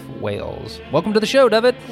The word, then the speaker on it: Dyfed: So, originally from the Welsh capital of Cardiff, Dyfed, pronounced Dyfed, is an award-winning actor, voice artist, and radio journalist with a passion for storytelling, movies, and sports.